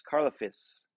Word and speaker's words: Karlaftis: Karlaftis. Karlaftis.